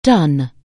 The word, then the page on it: do: anglais